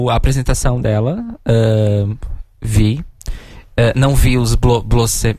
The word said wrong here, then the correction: Blues Mafia: é, eu falei certo isso? Uhum. É. – Blues, uma Blues, é, Blues Mafia. – Blues Mafia, Blues e Mafia.